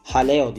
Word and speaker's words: Jaleo: Bunların arasında Talvez, Jaleo ya da Haleo diye okunuyor galiba bu tabi.